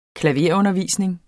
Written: klaverundervisning

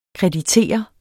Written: KREDITERER

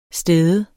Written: stede

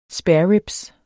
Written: spareribs

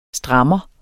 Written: strammer

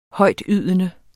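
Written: Højtydende

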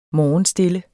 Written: morgenstille

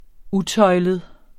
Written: utøjlet

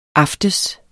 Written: aftes

